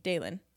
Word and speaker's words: Daelin: And it doesn't say shit about shit in the synopsis, except for it does say her name, which is Daelin is how it's spelled. – So that's Daelin. – Dae, Daylin. – Oh, Day. Okay, Daylin. Yeah. – When two vowels go walking, the first one does the talking, says its own name.